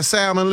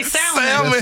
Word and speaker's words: salmon: Taisha, you got that salmon left? – Did you say salmon? – Salmon. Yes, sir. – No, don't pronounce the L.